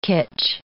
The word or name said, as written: kitsch